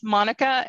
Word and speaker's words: Monica: so so it's Monica M O N I C A.